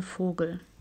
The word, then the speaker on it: Vogel: Der goldene Vogel. – Golden Frugel. – Frugel. Vogel. – Vogel. Oh, Vogel. – Like, oh, Vogel.